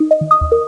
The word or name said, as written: depart